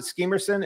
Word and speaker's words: Skemerson: Is that how you pronounce it? Skemerson in South African, Afrikaner? – Skemerson, yeah.